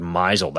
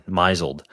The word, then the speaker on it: misled: I would use this word misled. I'd be like, he totally misled us. Like, what do you mean misled? And eventually I learned that it is actually pronounced misled. But that's how bad I am with reading words versus saying them.